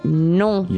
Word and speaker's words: non: But as he's leaving, yeah, the guy says, mais non. – Mais non. But no. Mais non. Now, when you say no in French, you say it in your nose. So instead of no, you say non.